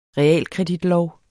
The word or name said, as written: REALKREDIT